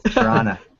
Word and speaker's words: Toronto: No, you don't pronounce a T, man. Toronto.